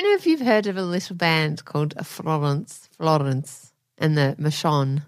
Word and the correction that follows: Florence and the Machine: Florence and the Machine.